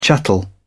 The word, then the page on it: chattel: British English